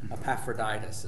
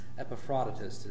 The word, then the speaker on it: Epaphroditus: I've heard that pronounced like 10 different ways from Sunday. Epaphroditus is one of the most common alternative pronunciations for that. Epaphroditus is the actual, well, the closest in the Greek that I can figure out.